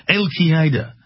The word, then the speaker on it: Al-Qaeda: That's how they say it in England. Al-Qaeda.